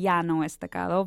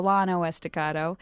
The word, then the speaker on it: Llano Estacado: And if you were to pronounce it in Spanish, it would be Llano Estacado. But in Texas, we say the Llano Estacado.